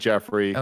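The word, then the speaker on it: Geoffrey: Which I said Joffrey, but everyone told me it's not. – Yeah, I looked up the pronunciation video for it. It's definitely that. Jeffrey.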